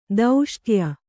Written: ದೌಷ್ಟ್ಯ

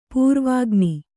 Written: ಪೂರ್ವಾಗ್ನಿ

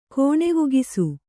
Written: ಕೋಣೆವುಗಿಸು

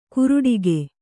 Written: ಕುರುಡಿಗೆ

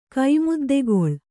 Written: ಕೈಮುದ್ದೆಗೊಳ್

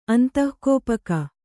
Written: ಅಂತಃಕೋಪಕ

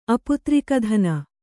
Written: ಅಪುತ್ರಿಕಧನ

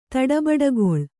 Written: ತಡಬಡಗೊಳ್

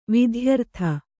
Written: ವಿದ್ಯಾರತೆ

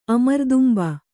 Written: ಅಮರ್ದುಂಬ

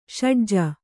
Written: ಷಡ್ಜ

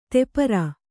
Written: ತೆಪರ

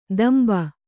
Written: ದಂಬ

